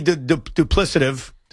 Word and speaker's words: duplicative: I'm not trying to be duplicative. – Duplicative? Duplicative? Duplicative. You're right. Yeah. – You said it right. – I did say it right.